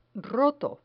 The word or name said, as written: roto